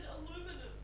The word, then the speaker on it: aluminium: You just said aluminum. – Yeah, well, that's because he said aluminum. – Aluminium. – We say aluminium, but he says aluminum.